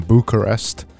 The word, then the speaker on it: Bucharest: They're from Bucharest think I've said that right. – Yeah, I think so.